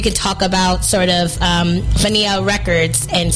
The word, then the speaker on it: Fania: The Fania, am I saying that right? – Mm-hmm. Fania.